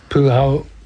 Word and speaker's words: Puao: And see, Palmerston North voted to keep the Te Puao, is that how you pronounce it? Te Pua Māori Ward? – Yeah. – P-U-A-O? – P-U-A-O.